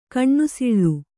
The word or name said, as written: ಕಿನಿಸಾಳು